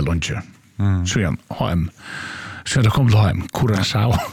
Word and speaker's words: Curaçao: Och, ja, och vi kommer att säga att landanavnet Curaçao uttalas Cura, K-U-R-A-S-A-U av vårskan Curaçao.